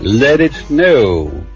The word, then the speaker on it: Let It Snow: No, con R no. Let It Snow, Let It, Let It.